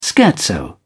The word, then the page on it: scherzo: British English